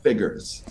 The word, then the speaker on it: Figures: Frank, is his last name Figures, he pronounces it? – Figures.